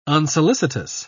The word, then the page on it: unsolicitous: British English